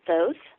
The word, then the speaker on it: Thoth: And Thoth, and that's how it's said, is it not, Thoth? – Well, I call him Thoth, and he, in all the years I've been working with him, he's never corrected me once.